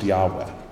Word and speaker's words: Yahweh: The word Lord, Hebrew and Greek, is a placeholder for the divine name, usually pronounced Yahweh.